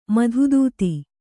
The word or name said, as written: ಮಧುದೂತಿ